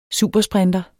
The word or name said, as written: supersprinter